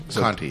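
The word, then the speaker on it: Conte: C-O-N-T-E. I'm saying it right. Conti? – Conti, yes.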